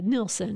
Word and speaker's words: Nilsson: Is his name pronounced Nilsson or Nielsen? I can't figure it out. – Yeah, Nilsson.